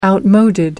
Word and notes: outmoded: American English

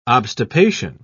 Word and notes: obstipation: British English

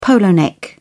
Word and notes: polo-neck: British English

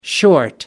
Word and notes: short: American English

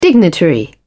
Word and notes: dignitary: British English

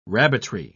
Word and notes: rabbitry: British English

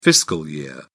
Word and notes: fiscal year: British English

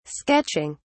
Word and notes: sketching: British English

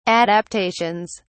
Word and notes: adaptations: British English

